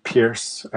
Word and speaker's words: Pearse: I'm also on Twitter at Matthew Pearce, and that's my middle name, and it's spelled a bit weird. It's P-E-A-R-S-E, so Matthew P-E-A-R-S-E.